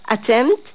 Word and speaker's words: attempt: أما make an attempt يعني يعمل محاولة، attempt attempt attempt. هنا في p لكن احنا مش بننطقها silent.